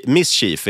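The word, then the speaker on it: MSCHF: Nej, men de är ju det här konstkollektivet då som står bakom oss, för det är Mischief, tror jag det uttalas. – Just det, Mischief är det förstås.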